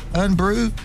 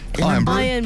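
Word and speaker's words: Irn-Bru: A theft of a lorry containing 1,000 bottles of, is it Urn Brew? – Iron Brew. – It's spelled IRN. – No, it's not. – It's because it's Scottish. Iron Brew. – Iron Brew. – Iron Brew. – Iron Brew. – It's spelled for it's pronounced. A lot of it's gone missing.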